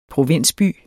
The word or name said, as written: provinsby